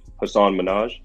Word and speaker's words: Hasan Minhaj: People think that we have a majority rules voting system, and I learned this from the Patriot Act with Hasan Minhaj. I said his name right. – Correct. Yeah.